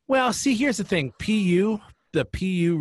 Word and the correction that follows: Pew: Research Center. – Pew. Yes. – Is that how it's pronounced? – Pew.